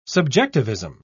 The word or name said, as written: subjectivism